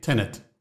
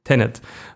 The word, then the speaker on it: Tenet: Wir reden über Tenant, habe ich es richtig gesagt? – Na. – Wie heißt es richtig? – Tenet. Tenet.